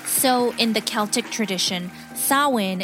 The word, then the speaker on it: Samhain: Samhain is the name Halloween in Irish, which has got an unusual spelling, S-A-M-H-A-I-N. – But it's pronounced Samhain.